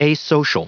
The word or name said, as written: asocial